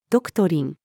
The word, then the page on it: ドクトリン: female